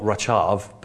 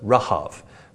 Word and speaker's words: Rahab: This is spelt not Rachav, but Rahav, Rahab.